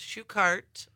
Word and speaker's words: Schuchart: Some people in the family even say it differently. It's Schuchart, like a shoe cart.